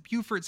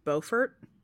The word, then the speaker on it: Beaufort: If I recall correctly, in South Carolina, it's pronounced Beaufort.